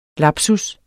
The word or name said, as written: lapsus